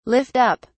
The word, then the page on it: lift up: British English